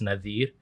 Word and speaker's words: Nadir: So like my brother is, his name is spelled Nazir, but it's Nadir.